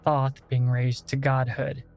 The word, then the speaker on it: Thoth: And I know that there's plenty of different ways to pronounce his name. Tot, Tot, Toth, like there's so many. When I was growing up, a little kid, reading about Egypt, researching it all on my own, because that's just the kind of kid I was, in my head it was pronounced Thoth. So whenever I mention him throughout this entire series, I'm going to say Thoth, because that's how I like to pronounce it.